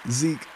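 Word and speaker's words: Zeek: My name is Zeke Burse. That's spelled Z-E-E-K. B as in boy, U-R-S-E. If you're in London, it's Z-E-E-K.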